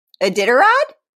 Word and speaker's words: Iditarod: Iditarod? – Yeah, that's how you say it.